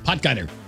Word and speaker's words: Potgieter: What's the official pronunciation? – Potgieter.